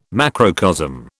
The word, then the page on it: macrocosm: British English